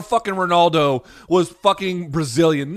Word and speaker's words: Ronaldo: his name is 100% pronounced Ronaldo. You pronunciation yes.